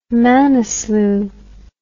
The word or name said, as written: manaslu